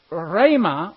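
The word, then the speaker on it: rhema (ῥῆμα): The word here is rhema, R-E-M-A. And that's an eta instead of an epsilon, and it sounds like an A. So it's pronounced rhema, R-E-M-A.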